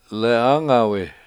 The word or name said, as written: lEE Angawe